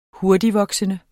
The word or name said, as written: hurtigtvoksende